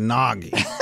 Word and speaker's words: Nagy: Actually, his name's Nagy. – It rhymes with eggnog.